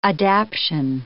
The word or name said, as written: adaption